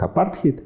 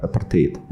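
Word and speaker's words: apartheid: Слово имеет э, происхождение с языка африканца. Да, поэтому правильнее а, называть да, эту политику и произносить как апартхейд. Но а, в русском языке закрепилась транслитерация с английского языка апартеид. Хотя и то, и другое, в принципе, допустимо.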